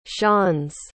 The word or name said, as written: schanz